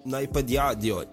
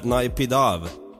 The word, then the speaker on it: Naypyidaw: Och huvudstaden som jag kan fan inte uttala den, Naypyidaw. – Naypyidaw. Ja, där. Naypyidaw.